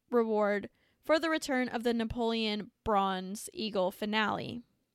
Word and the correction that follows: finial: Finial. That's how you pronounce it.